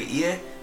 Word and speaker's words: ie: This was considered the basic family unit, or the ie, i.e., that's how it's pronounced.